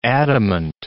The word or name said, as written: adamant